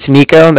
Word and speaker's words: Niko: My name's, like I said, my name's Nico. That's spelled N-I-K-O. And then I'm calling from the Bank of Cardiff. And that's spelled C-A-R. D-I-F-F, as in Frank Frank.